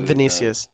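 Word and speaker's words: Vinícius: Vinicius, is that how you say his name? – But, Vinicius, yeah.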